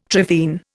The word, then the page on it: trephine: Indian